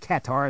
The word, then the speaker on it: Qatar: That's how I pronounce it. I don't know if other people pronounce it Qatar or whatever. You know, there's other ways to pronounce the country, but I pronounce it Qatar, and that's what I'm going to do.